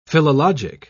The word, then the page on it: philologic: British English